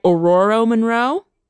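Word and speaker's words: Ororo Munroe: Aurora Monroe? – Yeah.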